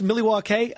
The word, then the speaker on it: Milwaukee: Isn't it pronounced Milwaukee? Yes, it is, Pete.